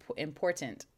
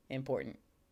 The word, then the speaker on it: important: and so uh, apparently I don't say it correctly. Um, imp- important is how you're supposed to say it. And I definitely say important uh, int, no T, import Uh, anyway.